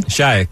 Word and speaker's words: Šajek: I like the kid, Šajek, I believe that's how you pronounce his last name. – Šajek, yep.